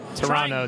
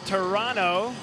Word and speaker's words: Toronto: Toronto, Trying- don't don't pronounce the T. Don't pronounce my Canadian friends will be very upset with you.